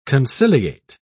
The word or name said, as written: conciliate